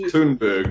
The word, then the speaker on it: Thunberg: It's pronounced Thunberg, number one.